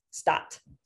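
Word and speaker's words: Stadt: It's S-T-A-D-T. – So I'm pronouncing it like S-T-A-T though. Stot.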